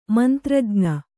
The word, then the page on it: ಮಂತ್ರಜ್ಞ: mantrajña